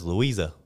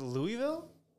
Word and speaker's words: Louisa: What? Louisa? – That's what it says, Louisa. – Is it Louisville? – That's not how it's spelled. – You're fucking with me, right? Yeah, I'm going to look it up. – Do you know what Louisville looks like? – I know.